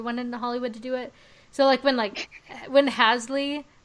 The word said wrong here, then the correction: Halsey: is that how you say her name? – Halsey? – Halsey. Yes, thank you.